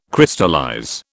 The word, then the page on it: Crystallize: British English